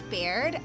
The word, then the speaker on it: Baird: I've only read your last name up until now. I assume that I just pronounced it right. How are you doing? – It's Baird.